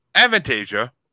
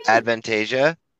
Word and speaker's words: Avantasia: Well, Avantasia And yes, that's how it's pronounced because you couldn't pronounce it in my birthday bracket. But that's how I pronounced it, and I didn't know. So yay me.